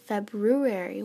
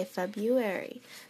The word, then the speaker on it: February: How come February is spelled February when we say February? Maybe we've been just saying it wrong, so maybe I should say February.